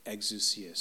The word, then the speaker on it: exousia: is it's probably pronounced exousia, but I like to say exousia. But let's just say it the way that the Greek thing on the internet told me to pronounce it, exousia.